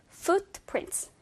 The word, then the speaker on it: footprints: Footprints라고 해서 앞에서는 이 foot 사운드이기 때문에 두 개의 입술이 붙어 있으면 안 되고요.